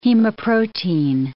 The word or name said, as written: hemoprotein